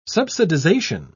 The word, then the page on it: subsidisation: British English